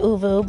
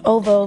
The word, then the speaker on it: ovo: Some people pronounce it uvu, but it's ovo vegetarian.